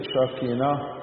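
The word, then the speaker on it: شاكينا (שכינה): تي اسكيني الكلمة القبطي دي جاية من العبري، العبري يتنطق شاكينا، وهي الكلمة نفسها العربي بتاعت يسكن، فتي اسكيني يعني المسكن.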